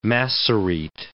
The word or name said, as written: Massorete